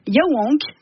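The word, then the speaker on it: Yaouank: Et Nora, à Rennes, on va pouvoir danser au festival Yaowank. – Ouais. – C'est ça, se prononce comme ça.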